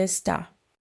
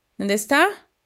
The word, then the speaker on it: ¿Dónde está?: And in Spanish, that's ¿dónde está? – ¿Dónde está? – Say it with her. ¿Dónde está? ¿Dónde está? Four syllables. The stress hits the last one. Está. ¿Dónde está?